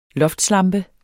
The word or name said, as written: loftslampe